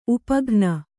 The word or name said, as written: ಉಪಘ್ನ